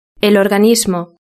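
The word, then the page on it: el organismo: Spain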